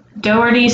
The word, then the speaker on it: Dougherty: And it's Dougherty spelled like D-O-U G-H like dough and then E-R-T-Y.